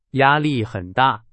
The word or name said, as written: 压